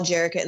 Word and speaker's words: Jerrika: So you can find me on Facebook, Instagram, and TikTok, all Jerrica in the Wild. My name is spelled J-E-R-R-I-K-A, and then just in the wild, just exactly how it sounds.